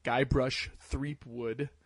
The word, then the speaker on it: Guybrush Threepwood: It's a fun, very cute, very funny game, where you kind of, you know, play, Guybrush Threepwood as he journeys across, Monkey Island.